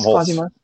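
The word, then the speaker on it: Hulce: Tom Holtz.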